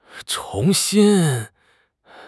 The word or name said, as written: chong2 xin1